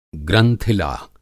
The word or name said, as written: ग्रंथिला